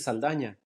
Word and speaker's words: Saldana: Because my last name is Saldana. But I always I always have to last second decide, am I going to say my name in English or in Spanish?